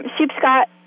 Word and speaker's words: Sheepscot: So if somebody wanted to come and visit you, they would find you on Sheepscot Farm, Sheepscot, wait, how do I pronounce it? – Yes, you're right, Sheepscot General.